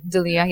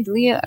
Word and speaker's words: D'Elia: I always I forget how to pronounce his name. D'Elia. Yeah, it's D'Elia. D'Elia. – Mm-hmm, D'Elia.